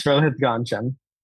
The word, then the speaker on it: Rohith Ganjam: Did I say it correctly? – Yes, Rohith Ganjam.